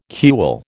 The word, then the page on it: cule: American English